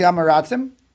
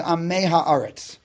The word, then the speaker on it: Am Mei Ha'aretz: Proper way to say is Am Mei Ha'aretz. Okay, putting the plural on the front end, not on the back end.